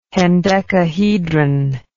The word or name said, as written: hendecahedron